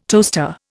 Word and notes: toaster: Indian

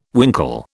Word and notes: winkle: British English

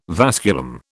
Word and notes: vasculum: British English

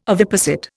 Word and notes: oviposit: Indian

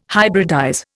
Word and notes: hybridize: Indian

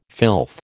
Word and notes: filth: American English

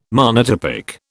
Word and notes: monotypic: British English

